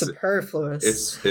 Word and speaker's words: superfluous: I remember always thinking that word was fun because it's like the first two syllables are super. Gosh. So you think you say super. – Right. – But you stress the superfluous. – Superfluous.